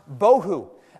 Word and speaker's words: bohu: Okay, so we hear that, and it doesn't really resonate with us, but do you know how to pronounce the word for void? It's pronounced bohu.